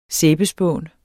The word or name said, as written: sæbespån